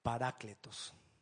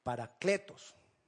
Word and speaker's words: paracletos: Parácletos. Paracletos. Es paracletos. Es con acento en la E.